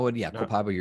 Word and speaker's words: Copaiba: Just through smelling, let me see if I pronounce this right. Copaiba, did I say that right? – Not inhalation. No. Copaiba, you're right.